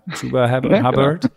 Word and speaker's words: Chuba Hubbard: Chuba Hubbard.